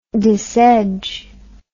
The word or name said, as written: disedge